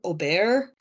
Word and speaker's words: Aubert: I would say Claude or Claude Aubert, because I think that the T is supposed to be silent.